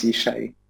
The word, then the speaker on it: Jishai: I'm Dutch originally, so the J is pronounced like English Y, just like Germans do. So yeah, it's Jishai.